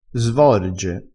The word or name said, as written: svorge